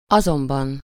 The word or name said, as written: however